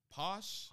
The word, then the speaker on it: Pausch: Posh. – Posh. – It's like PAUSCH.